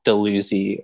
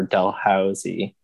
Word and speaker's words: Dalhousie: and like the pronunciation of Dalhousie or Dalhousie, or as I found recently, a different one, Dalhousie.